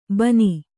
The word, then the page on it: ಬಾನ: Kannada